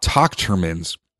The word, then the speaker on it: Tochterman: So I'm now tying flies for talk Tochterman's. Sorry for the uh, stutter there. It's Tochterman. If you listen to the podcast last year, it's pronounced like Doctor Man.